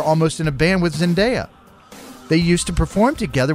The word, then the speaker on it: Zendaya: Zendaya, yep.